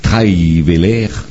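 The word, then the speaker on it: Trierweiler: Ainsi, nous apprenons que Madame Valérie Trahivelaire, hein, c'est ça. – On l'a bien prononcé en plus.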